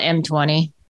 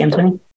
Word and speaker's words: M20: How do we say it? Is it 1.20 or like, I don't know the. – We call it M20. – Oh, is that externally as well? We say M20.